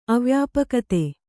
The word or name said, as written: ಅವ್ಯಾಪಕತೆ